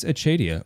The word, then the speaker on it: Acedia: Achadia is the more traditional pronunciation because it's a Latin word. – It's Acedia. – And if you were to say it in Latin, it would be Achadia.